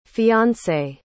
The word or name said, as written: fiancée